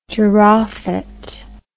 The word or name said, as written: durophet